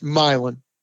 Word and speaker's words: Milan: It's a story based upon real life where a team from a tiny town called in real life Milan, spelled like Milan, Italy, but pronounced Milan, a little Indiana town back when many moons ago, the Indiana State Tournament was an open tournament.